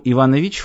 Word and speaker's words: Иванович: Причем Иванович — фамилия. Ударение на последнем слоге. Это важно».